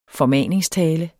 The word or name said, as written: formaningstale